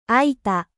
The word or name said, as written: ouvert